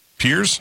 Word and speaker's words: Pierz: I'm a teacher in, is it pronounced P-I-E-R-Z? – Piers? – Piers, right?